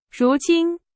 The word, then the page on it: 如今: Chinese